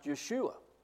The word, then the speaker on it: Yeshua: Jesus is a transliteration from the Hebrew name, which would have been pronounced Yeshua, or even Joshua, because the letters are identical.